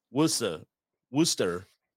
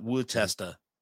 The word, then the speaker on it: Worcester: Greg texted me and said, Worcester, not Wootesta. So Worcester. There you go, the woo-woo.